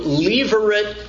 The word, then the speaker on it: Levirate: Levirate law. That is spelled like Levi, L-E-V-I, and then rate, R-A-T-E. One word, pronounced Levirate, the Levirate law. That law exists, is named out of the Latin term levir, L-E-V-I-R, which means husband's brother.